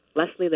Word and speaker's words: Lesley: My first name is spelled L-E-S-L-E-Y. So there you go. Pretty easy to pronounce. Both Scottish. Leslie Livingston.